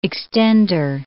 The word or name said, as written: extender